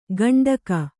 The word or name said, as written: ಗಂಡಕ